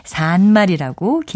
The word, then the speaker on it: 산말: 동사 살다와 관련된 것이니까 이건 역시 산말이라고 길게 발음하고요.